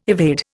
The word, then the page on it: evade: Indian